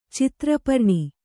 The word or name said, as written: ಛತ್ರಪರ್ಣಿ